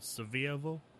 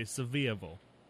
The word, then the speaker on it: Sevierville: When we got married, we went to right outside of Pigeon Forge in a place called Sevierville. – Okay, never heard of it, but I feel you. – You have to say it like that. You have to say Sevierville. – Sevierville? – Yeah, because you can't say Sevierville.